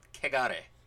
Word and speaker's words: kegare: And, like, if we're talking about impurity in Shinto, we are talking about kegare. Am I pronouncing yes. – that right? – Kegare.